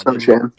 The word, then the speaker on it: Sochan: Now, Sochan, or however you pronounce it, I'm sorry if I pronounce it wrong. Hopefully, it's Sochan.